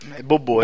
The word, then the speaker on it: Bobo: Como que será que pronuncia, né, cara? Porque bobo fica foda, né? Foda falar bobo. – Bobô, é. – Bobô, né? – Bobô. É o que eu. Era a pronúncia que eu escutava no filme, é bobô.